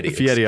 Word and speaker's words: Fieri: Fieri, I believe. Don't look at how it's spelt. – Right. – Look only at how it feels to say.